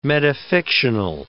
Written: metafictional

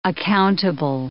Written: accountable

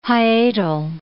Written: hiatal

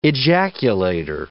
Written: ejaculator

